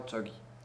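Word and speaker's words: chogi (저기): la prononciation, la particule, le démonstratif, c'est cho. Mm. Cho. Et pour le lieu, ça devient chogi.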